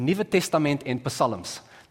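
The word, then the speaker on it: Nuwe Testament en Psalms: It's an Afrikaans Bible, the Nieuwe Testament en Psalms. That's how you say it, the New Testament and Psalms.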